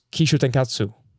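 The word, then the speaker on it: Kishōtenketsu: Japan uses Kishu Tenkatsu for theirs. – You actually got that right. I'm impressed.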